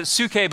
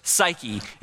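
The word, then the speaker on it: psuche (ψυχή): The Greek word that we find in the New Testament for soul is actually, in Greek we'd pronounce it psuche, but we kind of say it in English, psyche.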